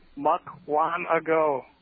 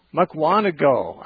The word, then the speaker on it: Mukwonago: Our next call comes from Mukwonago, Wisconsin. I think I finally say that correctly. – Hey, good afternoon. Mukwonago. – Mukwonago. I always put the accent on the wrong syllable.